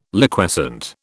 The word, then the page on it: Liquescent: British English